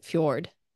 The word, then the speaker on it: fjord: I think the J is silent. Fjord.